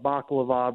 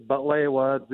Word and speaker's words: butlewa: A lot of people call it baklava, but we had a big argument last year, you and me, Jeff, about how to pronounce it. It's butlewa, the Arabic way.